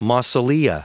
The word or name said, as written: mausolea